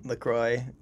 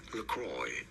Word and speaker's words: LaCroix: In the U.S., this is said as Lacroix. Lacroix, from French in France, it is said as La Croix, La Croix meaning the cross.